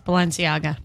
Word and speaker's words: Balenciaga: Do you know of a Spanish fashion brand called Balenciaga? Is that how you say it? – Yes, Balenciaga. – Did I say it right? – Yeah, that was good.